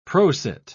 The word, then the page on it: prosit: British English